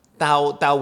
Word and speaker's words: Tao: And actually in Mandarin it should be pronounced Tao with a D, all right? Mm. But normally when it's written or anglicized, they will write it with a T.